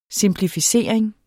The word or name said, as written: simplificering